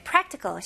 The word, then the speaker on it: practical: 네, practical, 실용적인, 실질적인 이라는 뜻들을 가지고 있고요. 발음 연습부터 해볼게요. practical, practical. 여기에서는 이제 prac라고 할 때 첫 번째 그 C 발음을 그렇게 강하게 안 하고